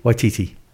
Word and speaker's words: Waititi: So, uh, so yeah, um, that's uh, uh, Taiki Waititi. Taika Waititi. Taika. Taika. Taika. Taika Waititi. Waititi.